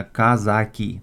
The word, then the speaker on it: казаки: И для начала нужно сразу оговориться, что для современного русского языка правильно говорить именно казаки, то есть два А должно быть.